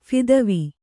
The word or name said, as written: ಫಿದ್ವಿ